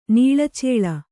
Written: ನೀಳಚೇಳ

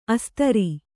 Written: ಅಸ್ತ್ರಿ

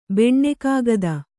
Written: ಬೆಣ್ಣೆಕಾಗದ

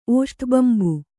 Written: ಓಷ್ಠಬಂಬು

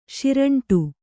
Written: ಸುರುಂಟು

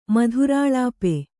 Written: ಮಧುರಾಲಾಪೆ

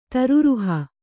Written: ತೀರರುಹ

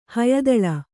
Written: ಹಯದಳ

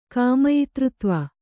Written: ಕಾಮಯಿತೃತ್ವ

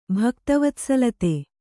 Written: ಭಕ್ತವತ್ಸಲತೆ

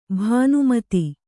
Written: ಭಾನುಮತಿ

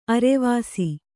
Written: ಅರ್ವಿಸು

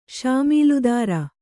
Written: ಶಾಮೀಲುದಾರ

